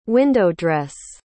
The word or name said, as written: window-dress